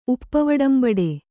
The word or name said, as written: ಉಪ್ಪವಡಂಬಡೆ